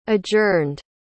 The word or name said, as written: adjourned